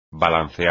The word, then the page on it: balancear: espagnol